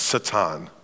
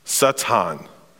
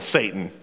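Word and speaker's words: Satan (שטן): Uh, you can tell them that this is in, in Hebrew, it's pronounced Satan. Satan. But since we're in Missouri, we'll just say it like normal Satan.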